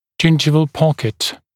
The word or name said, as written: gingival pocket